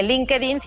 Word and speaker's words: LinkedIn: Eso hizo que yo llegara a esta red social, eh, digamos que conocida en el mundo profesional que se llama LinkedIn, eh, LinkedIn si lo pronunciamos como en español.